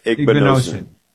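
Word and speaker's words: Igbinosun: So, you know, the Ohio State kid, is it Igbinosen? How do you pronounce his name officially? – Igbinosen.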